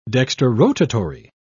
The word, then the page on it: dextrorotatory: British English